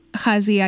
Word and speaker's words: Gaza: to Gaza, I guess that's how you say it. – Yeah. – Okay, I was saying Ghazi, but yeah, pretty close. And then also in parallel, there is the story of Khalil, who just found also refuge in Ghazi with his family from Syria.